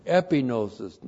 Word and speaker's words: epignosis: Epignosis, E-P-I-G-N, long O-S-I-S.